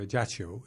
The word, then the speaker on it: Ajaccio: And I can tell you the headline is Zaytuna is halfway to Ajaccio.